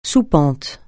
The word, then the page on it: soupente: francais